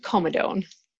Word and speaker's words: comedone: First of all, how do you pronounce a whitehead or blackhead in a fancy medical? I hesitate saying it because I don't know what I'm supposed to call it. – I pronounce it comedone.